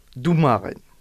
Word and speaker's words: domaren: Qui s'écrit L-A-G. Un arbitre, domaren. – Domaren. – Le O dans ce mot se prononce ou.